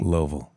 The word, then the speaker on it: Louisville: Yeah, I kid you not. That's how they say it. They'll get mad if you don't say it that way. – Louisville.